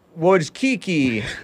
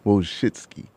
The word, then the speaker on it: Wojcicki: I think I seen two different ways of pronouncing it. It's Woo and Woe. Wojcicki.